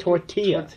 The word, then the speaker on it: tortilla: Tortilla. You don't pronounce the L's.